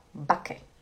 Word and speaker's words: backe: Lembra que a gente estudou na segunda-feira a pronúncia do CK? A gente fala backe, backe, né? O CK ele é rápido. Backe, backe.